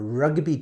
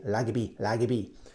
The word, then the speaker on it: rugby: And I remember my friend went on a rugby tour. Make sure you say the R correctly, yeah? Rugby. In Japan, sometimes we say lagby. But please say correct pronunciation. Rugby.